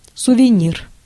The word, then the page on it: keepsake: American English; [ˈkiˑpseɪk]